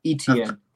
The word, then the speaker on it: Etienne: And then we got, how do you pronounce his name? – Etienne. – Etienne, okay. And he's the guy from Clemson.